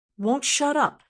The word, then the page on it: won't shut up: American English